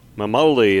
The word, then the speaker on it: Memoli: Memoli.